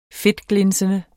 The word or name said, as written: fedtglinsende